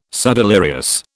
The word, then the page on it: subdelirious: British English